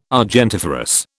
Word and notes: argentiferous: British English

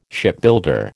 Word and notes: Shipbuilder: American English

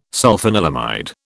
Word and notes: sulfanilamide: British English

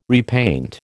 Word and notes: repaint: American English

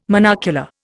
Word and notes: monocular: Indian

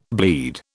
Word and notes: bleed: British English

